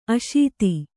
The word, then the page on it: ಅಸೇಧ: kannada